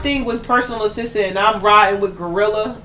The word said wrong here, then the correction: GloRilla: is that how you say her name? – Glorilla. – Glow? – Yeah. G to the L to the OG Glow.